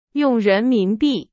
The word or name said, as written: 人民币